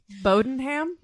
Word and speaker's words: Bowdoinham: Bowdenham? – Well, but this is how it's spelled before everyone judges me. Bowdoinham. Bowdenham is, that's it? – Bowdenham.